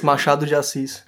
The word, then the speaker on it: Machado de Assis: Yeah, it's Machado de Assis.